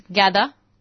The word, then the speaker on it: Gada: Yeah, my identity is Gada. – Could you spell that for our G-A-D-A? – Gada.